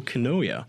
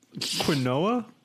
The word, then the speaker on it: quinoa: It's pronounced the way it doesn't look. – Okay. – Like you're pronouncing it the way it looks, but it's quinoa.